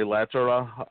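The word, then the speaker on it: Lehterä: The Flyers got a player from Buffalo named Jori Latera, and the accent is on the first syllable because it's a Finnish name. People call him Latera, but anyway, he's a good two-way player.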